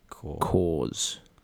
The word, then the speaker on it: Corps: Oh, there was these army men called Cors. And they were spelled like Corps, which I believe is pronounced Cors.